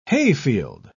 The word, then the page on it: hayfield: British English